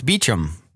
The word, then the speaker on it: Beauchamp: Although Dr. Bruce Lipton said he was in England and going to a hotel that was Beauchamp, and he said Beauchamp, and they said, oh, that's Beecham, which I guess that's how they pronounce it there, even though originally the French pronunciation.